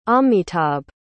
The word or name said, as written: amitabh